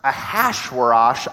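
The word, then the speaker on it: Achashverosh: But in Hebrew, it's pronounced Ahasuerash. I only mention that because phonetically, if you were Hebrew and you heard the word Ahasuerash, it would have sounded like the word headache.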